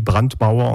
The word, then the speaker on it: Brandmauer: Est-ce que ce fameux cordon sanitaire, le Brandmauer, si je prononce bien, tient toujours? Et est-ce qu'il va tenir? – Pour l'instant, il tient, oui. Il Brandmauer, on dit, oui, c'est le pare-feu, hein, comme on dit.